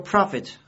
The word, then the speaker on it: prophet: 另外这个 prophet 和利益那个 prophet 的发音一样，我们要把两者呢区别记忆。